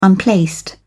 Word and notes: unplaced: British English

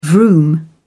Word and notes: vroom: British English

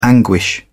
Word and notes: anguish: British English

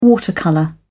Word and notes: watercolour: British English

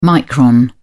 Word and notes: micron: British English

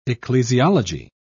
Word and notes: ecclesiology: British English

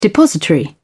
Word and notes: depository: British English

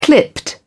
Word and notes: clipped: British English

